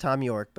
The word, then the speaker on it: Thom Yorke: It's pronounced Thom Yorke, but yes. – Well, that's how he spells it. Thom Yorke. – English spelling of Tom from Thomas, which has an H in it.